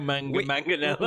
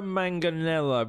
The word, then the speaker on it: Manganiello: You don't say manga twice. You don't go Joe Manganiello.